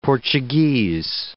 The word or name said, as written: Portuguese